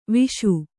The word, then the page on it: ವಿಷ: Kannada